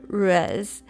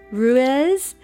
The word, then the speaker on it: Ruiz: The Four Agreements by Don Miguel Ruiz. I always say his name wrong. Ruiz.